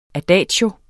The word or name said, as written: adagio